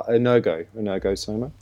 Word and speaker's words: energosoma: And that's, you know, with the psychosoma, the mental soma, and I do not know how to pronounce the energosoma. – Oh, energosoma. – Energosoma. All right, yeah. I got close.